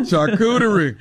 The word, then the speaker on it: charcuterie: That charcuterie.